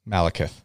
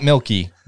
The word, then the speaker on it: Malekith: Malekith. – Malekith. – Not Milky. Malekith.